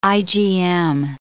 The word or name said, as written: IgM